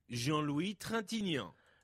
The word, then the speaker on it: Jean-Louis Trintignant: Jean-Louis Trintignant. – Jean-Louis Trintignant, who was a staple of French cinema from the late 50s on.